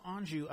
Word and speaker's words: Anju: So Anju, did I say that right, Anju? – You did.